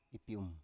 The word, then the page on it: ipium: Biak